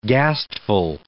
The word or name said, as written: ghastful